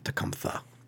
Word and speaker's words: Tecumseh: It's actually pronounced, I believe, Tecumseh, but so we're not going to hold you responsible.